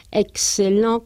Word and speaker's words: excellent: De manera que decimos, o bien dos Cs, excellent con KS, ¿eh? Excellent, accident, fixé. Esa X la pronunciamos como KS o esa doble C.